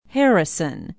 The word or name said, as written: harrison